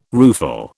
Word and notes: rueful: British English

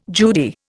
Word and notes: Judy: Indian

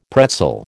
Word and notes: pretzel: American English

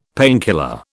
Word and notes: painkiller: British English

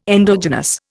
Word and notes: endogenous: Indian

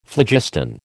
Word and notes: phlogiston: American English

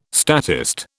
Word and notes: statist: British English

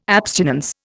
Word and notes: abstinence: Indian

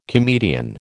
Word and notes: comedian: American English

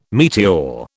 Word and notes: meteor: British English

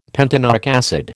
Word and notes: pentanoic acid: American English

